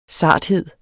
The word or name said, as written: sarthed